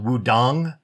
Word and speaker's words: Wudang: Wudan is actually a place, even though it's really spelled Wudang with a G sound at the end.